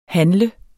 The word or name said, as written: handler